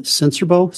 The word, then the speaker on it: Sincerbeaux: My name is David Sensorbo, spelled S-I-N-C-E-R-B-E-A-U-X.